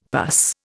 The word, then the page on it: pus: Indian